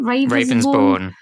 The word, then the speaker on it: Ravensbourne: Ravensbourne. – Oh my God. Why do you have to make names so complicated in this country? Ravensbourne University.